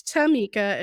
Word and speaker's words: Thamica: So my first name is Tamika, and it's spelled T-H-A-M-I-C-A.